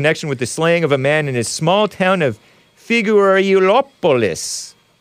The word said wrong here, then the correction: Figueirópolis: I butchered that name. Figueirópolis. I feel like I'm trying to pronounce diminutive. Hey, I think I pronounced it right this time.